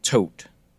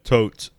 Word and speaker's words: Thoth: But you read about Thoth before, right? T-H-O-T-H is, I mean, phonetically, American phonetically, that's how you pronounce it, but the narrator pronounced it Tote. – Yeah, Tote is what I've heard.